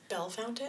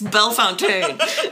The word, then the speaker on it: Bellefontaine: It's Bellefontaine. – It's Bellefontaine.